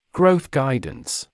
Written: growth guidance

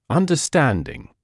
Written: understanding

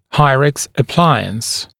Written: Hyrax appliance (Hygienic rapid palatal expander)

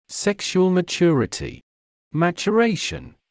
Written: sexual maturity/maturation

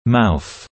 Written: mouth

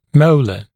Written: molar

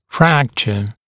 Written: fracture

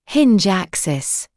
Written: hinge axis

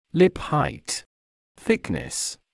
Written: lip height/thickness

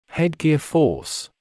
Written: headgear force

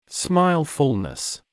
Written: smile fullness

